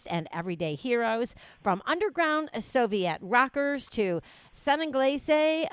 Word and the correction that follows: Senegalese: It's pronounced Senin. Can you pronounce it? – Senegalese. – Senegalese.